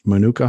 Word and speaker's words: Manuka: So, Monkey's Uncle is saying you've had Manuka honey. Am I saying that right? Manuka honey? – Yeah.